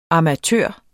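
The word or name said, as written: AMATØRER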